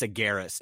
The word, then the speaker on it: Agares: And I pronounced it like, is Jennifer Ahares here? And she goes, it's a Garris, and it's a white girl.